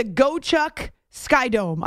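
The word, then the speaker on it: Gocheok Sky Dome: joined us from the field at the Gochuk Sky Dome. I asked him how to pronounce it so I wouldn't get it wrong. The Gochuk Sky Dome.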